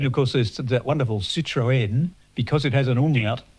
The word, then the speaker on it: Citroën: But, yes, pronounced Citroën. It's a bit like a Moët and Chandon. People go, it's Moët. No, it's not. It has a dilated E, so it's Moët. – Moët. Oh, okay. So you do pronounce the N on the end.